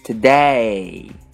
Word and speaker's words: today: 在这里，我们需要注意的单词发音是今天，today，双元音 a 以 a 开头，e 结尾，a a，请跟我读，today today today。